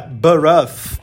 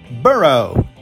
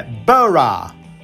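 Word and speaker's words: borough: Parece que ela vai ser pronunciada Baruff ou Barug. Não. A maneira de pronunciar com o inglês mais falado nos Estados Unidos é Burrow.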